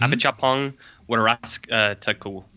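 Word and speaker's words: Apichatpong Weerasethakul: It's like Apakopong Werasathakul. – I'd like to interject because I know how to pronounce this name. Mm-hmm. Apakopong Werasathakul.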